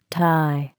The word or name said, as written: tie